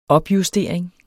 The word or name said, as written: opjustering